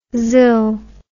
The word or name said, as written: zill